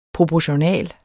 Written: proportional

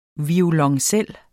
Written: VIOLONCELLEN

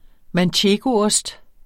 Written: manchegoost

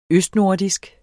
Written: østnordisk

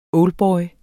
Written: oldboy

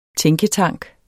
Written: tænketank

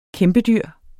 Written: kæmpedyr

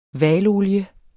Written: hvalolie

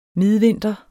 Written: midvinter

